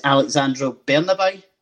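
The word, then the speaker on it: Alejandro Bernabei: And obviously, a player I want to kind of touch on, and I know if I'm going to get his pronunciation, is Alexandro Bernabei.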